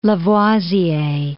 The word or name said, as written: Lavoisier